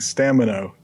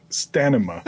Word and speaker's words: stamina: I imagine next week he's going to write in to inform you that you keep pronouncing stamina, stanima. – I know I do that.